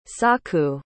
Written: saku

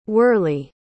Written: worley

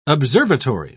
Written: observatory

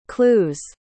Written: clue's

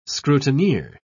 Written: scrutineer

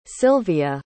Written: Sylvia

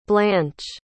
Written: blanche

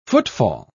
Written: footfall

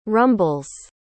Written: rumbles

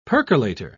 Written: percolator

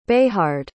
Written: bayhard

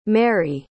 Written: merri

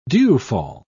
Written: dewfall

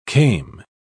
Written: came

